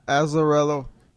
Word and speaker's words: Azzarello: Und zwar geht es darum, der Schreiber ist der Brian Azzarello. Hoffentlich ist das richtig ausgesprochen. – Azzarello. – Ja, der wird Azzarello. Azzarello.